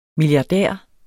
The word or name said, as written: milliardær